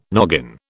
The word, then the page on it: noggin: British English